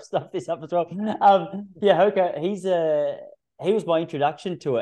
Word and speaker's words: Hoka: Hoka? – Yeah, Hoka.